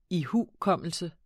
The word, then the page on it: ihukommelse: [ ˈihuˌkʌmˀəlsə ]; [ ihuˈkʌmˀəlsə ]; [ iˈhuˀˌkʌmˀəlsə ]